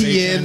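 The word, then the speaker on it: Etienne: I think it's ETN now. – It's ETN, but he changed his pronunciation or said the correct one.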